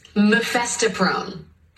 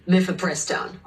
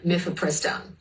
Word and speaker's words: mifepristone: Mephistoprone, however you pronounce the word. That is how Supreme Court Justice Samuel Alito apparently pronounced the name of the abortion medication, mifepristone. I'll say it again, mifepristone.